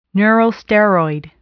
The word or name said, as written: neurosteroid